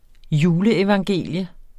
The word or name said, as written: juleevangelium